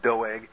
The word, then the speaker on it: Doeg: Except sometimes you get some real weird ones like Doeg the Edomite in 1 Samuel that the pastor calls Doug occasionally, and that really offends people named Doug. So if your name is Doug, I apologize, but it's probably more accurately to pronounce it Doeg.